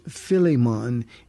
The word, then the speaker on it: Philemon: This is a wonderful name, but it's Philemon. It's just easier in the West to pronounce it Philemon because we like the long vowels and the emphasis on the different syllables. It's Philemon is how you say it.